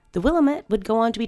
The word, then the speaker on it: Willamette: And Portlanders listening, don't freak out at me for pronunciation. It's spelled with an I, not an A. Willamette.